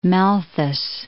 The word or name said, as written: Malthus